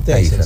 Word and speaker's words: Taser: Taser, sí, bueno.